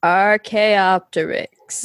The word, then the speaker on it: Archaeopteryx: But I also think the CH is a hard K sound. – Archaeopteryx.